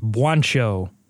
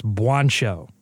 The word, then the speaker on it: Juancho: I believe it's pronounced Juancho Hernan Gomez. – Did I say Juancho? – I said it's pronounced Juancho, like Billy Hernan Gomez.